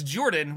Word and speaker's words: Jordan: Can't My name is Jordan, with a silent PH in the middle, and I'm joined always by my wonderful co-host, Sir Lucian, over at Sir Lucian Gaming.